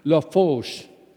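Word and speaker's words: Lafourche: And this little voice was there, and it said, La Fauche.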